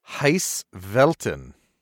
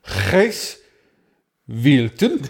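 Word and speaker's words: Gijs Velten: Well, I think that's uh, rather than Scandi, that might be Dutch, but uh, I'll give it a go anyway. It's um, uh, Gijs Wilton.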